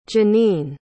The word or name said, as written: Janine